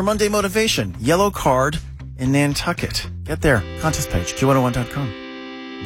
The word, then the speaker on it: Nantucket: Island off Cape Cod, East Coast, Nantucket. Rhymes with bucket.